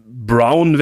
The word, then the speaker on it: Brown: Brown wäre da, also Brown, also so wie Braun, deutsch geschrieben.